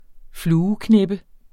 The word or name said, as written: fluekneppe